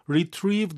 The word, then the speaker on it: retrieve: 우리 이제 영어 발음이니까 retrieve 이렇게 읽으면 돼요.